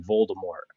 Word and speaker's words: Voldemort: She was like, no, it's actually not pronounced. You don't pronounce the T in Voldemort.